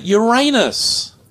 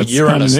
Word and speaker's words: Uranus: it was the discovery of the planet Uranus. – Yeah. – Ooh, or Uranus, depending on how you say it and how you were taught to say it at school.